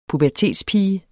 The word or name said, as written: pubertetspige